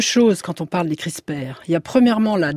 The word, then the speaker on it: CRISPR: Pascal Cossard, notre invité, avec ce livre chez Odile Jacob, La Nouvelle Microbiologie, des microbiotes au CRISPR.